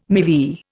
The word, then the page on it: MeV: Indian